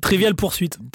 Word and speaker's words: Trivial Pursuit: Ja, also ja, hätte ich jetzt eher nur Scrabble und äh, Travel Pursuit. Wie spricht man das eigentlich aus? Kennst du Travel Pursuit? Kann, ich, Trivial Pursuit. Trivial Pursuit.